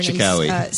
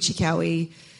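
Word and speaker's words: Cherkaoui: A remarkable choreographer Chikawi. – City Larbi Chikawi.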